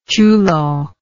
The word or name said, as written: chulo